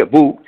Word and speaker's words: Boo: Bo, porque por algún motivo se escribe Bo y se pronuncia Bo,